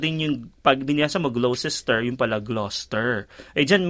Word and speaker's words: Gloucester: Yung pag pin, pinronounce mo, ah, pag binasa mo siya parang Gloucester. Pero yung pala, Gloucester.